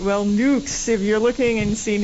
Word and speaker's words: Nux: Also, if you look in the paper and see N-U-X, I don't know how you pronounce that. Nux? – Nukes.